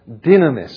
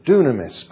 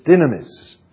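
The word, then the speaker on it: dynamis (δύναμις): And that word in the Greek language is the word dynamis, which is the way the Greeks pronounce it to this day, dynamis. And you might say, when you see it, dunamis, but it's dynamis.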